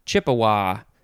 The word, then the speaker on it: Chippewa: Next is Chippewa. You could pronounce that a few ways. Chippewa, Chippewa, Chippeway, Chippewa. How many? It's like all the different ways you can say that last syllable. Capital C-H-I-P-P-E-W-A, Chippewa. Noun from 1671, and we just have the synonym Ojibwa, O-J-I-B-W-A.